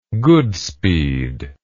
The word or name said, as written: goodspeed